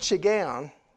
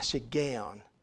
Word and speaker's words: shiggaion: Now, that word shiggaion, and it's taken me a while to get that pronunciation down because I didn't grow up saying it that way, but, but that's the pronunciation, a shiggaion of David.